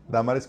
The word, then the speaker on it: Damaris: Damaris con acento en la primera A.